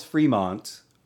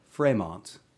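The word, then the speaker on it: Frémont: John Charles Fremont, or sometimes pronounced Fremont, with an accent over the E, was an American explorer, military officer, and politician.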